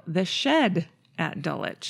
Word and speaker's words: Dulwich: Dulwich. – Which is spelled Dulwich.